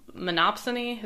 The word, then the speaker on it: monopsony: I have to say, that discussion we had about where you introduced monopsony, is that how you say it? – Yeah.